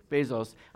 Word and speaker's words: Bezos: Bezos.